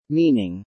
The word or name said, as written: meaning